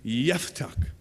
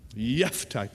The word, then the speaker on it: Jephthah: Now, Jephthah. Now, before we begin, there's two things we got to know. First, the correct pronunciation of his name is actually Jephthah. Jephthah. The J is more like a Y, you know?